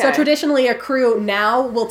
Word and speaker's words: Krewe: You may need the help of a crew to plan this properly. Crew is spelled K-R-E-W-E if it helps.